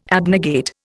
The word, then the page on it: abnegate: Indian